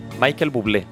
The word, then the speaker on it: Michael Bublé: Michael Bublé.